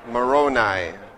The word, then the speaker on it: Moroni: Pronounce this Moroni. Moroni.